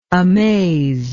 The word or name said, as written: amazed